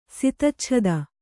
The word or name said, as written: ಸಿತಚ್ಛದ